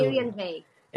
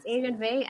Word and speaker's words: Airyen Vay: It's to Airyen Vay. – Airyen. Yeah, hi. Excellent. So my name is Airyen Vay.